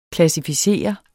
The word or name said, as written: klassificere